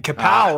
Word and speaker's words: Kapow: And Kapow rhymes with now, so that works out very well.